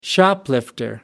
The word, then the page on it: shoplifter: American English